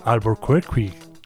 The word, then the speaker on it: Albuquerque: Siguiendo por Estados Unidos, por Norteamérica, Globalkerque, ya sé que por ahí lo dicen un poco diferente, Globalkerque, que hace referencia a la localidad de Albuquerque, Albuquerque, si lo pronunciamos a la manera gringa.